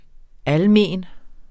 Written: almen

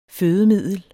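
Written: FØDEMIDDEL

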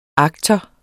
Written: aktor

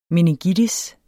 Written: meningitis

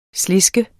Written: SLESKES

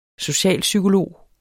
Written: socialpsykolog

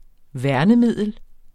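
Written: værnemiddel

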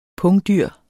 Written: pungdyr

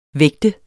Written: vægter

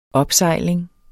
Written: opsejling

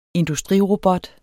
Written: industrirobot

